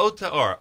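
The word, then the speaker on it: Ote or (עוטה אור): How do you say Oite. Oite or. Oite or. – Ote or. Ayin Vav Ted Hey.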